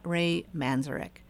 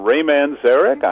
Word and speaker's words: Manzarek: Hi, I'm Ray Manzarek. I'm the keyboard player of the Doors. You put the accent on the second syllable, Manzarek.